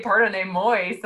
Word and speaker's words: pardonnez-moi: And thank you, Carol, for your pardonnez-moi, because that's just such a great, you know, you have to say pardonnez-moi so that it rhymes with destroy.